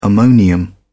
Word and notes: ammonium: British English

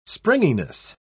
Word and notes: springiness: British English

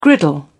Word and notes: griddle: British English

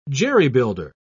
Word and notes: jerry-builder: British English

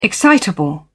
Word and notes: excitable: British English

